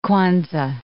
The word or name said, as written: Kwanzaa